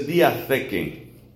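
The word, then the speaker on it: diatheke (διαθήκη): La palabra alianza en griego se dice diatheke. Eso se escribe como se oye diatheke, pero con th, diatheke.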